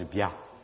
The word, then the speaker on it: Béal: Localement, en Auvergne, Béal, ça se prononce Bia.